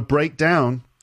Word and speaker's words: breakdown: So when it's a noun, the first syllable is stressed, breakdown. And when it's a verb, the second part is stressed, to break down.